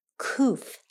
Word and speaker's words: Qoph: It starts with a Q, but it sounds like a K. Qoph is how you say this Hebrew word.